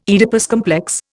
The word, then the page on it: Oedipus complex: Indian